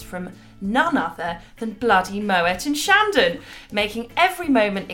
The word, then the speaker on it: Moët: Claude Moët, yes, I am saying it right, we'll explain why in a moment, founded the House Moët and Chandon in 1743.